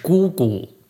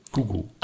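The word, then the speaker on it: Gugu (姑姑): Das ist eine Tante und das heißt auf Chinesisch Gugu. – Gugu. – Also Gu ist die Schwester deines Vaters und das hat die gleiche Aussprache wie Gu Dan Gu Du, einsam.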